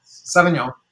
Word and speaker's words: Sauvignon: Sauvignon, yeah.